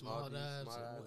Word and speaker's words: Maldives: Did I say it right? The Maldives? – Maldives. It's one of them.